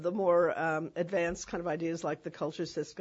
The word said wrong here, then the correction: culturist: guy who. Mm-hmm um, didn't uh, I think I'm not pronouncing that right, culturist. – Culturist, yeah.